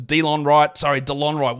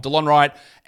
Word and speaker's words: Delon Wright: And lastly, we look at the Washington Wizards, DeLon Wright. Why do I say that wrong every time? DeLon Wright out until around the middle to end of December with a knee problem.